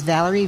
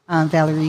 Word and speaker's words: Valarie: My name, again, is Valerie, V-A-L-A-R-I-E. Valerie is spelled different in so many ways, but V-A-L-A-R-I-E, Mackey, M-A-C-K-E-Y.